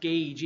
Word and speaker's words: Gage: Dr. Gage, I said gouge the last time I was speaking up in Greenville. And I was told later, I think it's pronounced gauge, even though it looks like gouge.